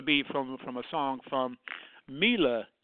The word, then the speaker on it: Mila: Just saying, I'm saying her name now as Mila, whereas before I was saying Mila, because I didn't know it was pronounced Mila. I now know her name is pronounced Mila J because I happen to have seen a Facebook posting of her yesterday giving a live interview.